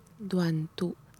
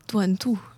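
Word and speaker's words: Đoàn tụ: Douanetu, comment on le prononce exactement ? – Duan-tu. – Duan-tu.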